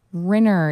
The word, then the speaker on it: Renner: I for some reason, especially when I was teaching, some of my students would call me Miss Renner, and so I started saying it's Renner. It rhymes with winner, not with wiener.